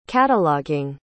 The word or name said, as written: cataloging